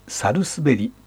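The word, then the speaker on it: 百日紅: 100 日に紅と書いて、猿滑りと読みます。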